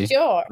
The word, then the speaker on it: Pasteur: Pasteur.